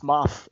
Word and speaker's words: Moff: Sorry to interrupt you, but no, it's Moth. M-O-F-F. – Yeah, M-O-F-F.